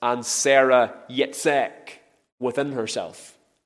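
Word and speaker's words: Yitzchaq: And when this wee boy was born, we read in verse 3 that Abraham called the name of his son Yitzhak. That's what it is in the Hebrew. I'll spell it for you. It's Y-I-T-Z-C-H-A-Q.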